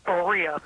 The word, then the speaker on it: Berea: I'm going to say Berea, Ohio. Did I pronounce that correctly? – It's Berea.